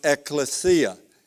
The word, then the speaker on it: ekklesia: K-L-E-S-I-A. And the word is pronounced ekklesia.